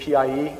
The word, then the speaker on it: PIE: They can have what's called Pocket Internet Explorer, which is abbreviated PIE and generally pronounced PI.